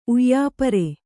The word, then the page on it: ಉಯ್ಯಾಪರೆ: uyyāpaṛe